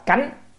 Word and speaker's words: cánh: Giảo chư thiên quỷ thần chữ này chúng ta đọc là cánh, cánh liệt,